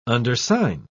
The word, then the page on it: undersign: British English